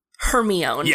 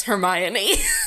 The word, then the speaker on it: Hermione: And then my mom was like, "It's Hermione," and I was like, oh. – "No, I don't think you're right. I'm pretty sure it's Hermione."